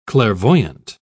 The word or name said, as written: clairvoyant